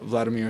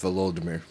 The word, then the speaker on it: Volodymyr: I'm not going to talk bad about any, but I will talk good about Vladimir Zelensky. – It's pronounced Volodymyr. – Volodymyr.